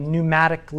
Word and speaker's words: pneumatic: It's where you get pneumatic. Have you ever seen that? The P is silent, like a pneumatic lift.